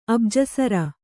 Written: ಅಬ್ಜಸರ

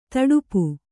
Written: ತುಡುಪು

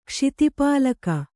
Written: ಕ್ಷಿತಿಪಾಲಕ

